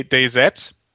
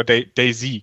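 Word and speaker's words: DayZ: Die große Geschichte ist halt ähm, oder ein anderes Beispiel ist halt Day, Day Z, oder Daisy, glaube ich, ist die offizielle Aussprache mittlerweile. Daisy, dieses, der, der Arma 2 Mod mit Zombies, wir hatten es schon ein paar Mal erwähnt.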